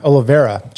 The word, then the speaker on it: Oliveira: Oliveira.